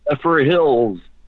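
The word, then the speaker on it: Zephyrhills: Zephyr Hills.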